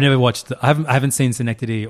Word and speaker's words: Synecdoche: Adaptation, Synecdoche, New York.